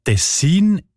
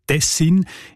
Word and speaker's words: Tessin: Die Variante mit der Betonung auf der ersten Silbe und mit kurzem I, also Tessin, ist seltener.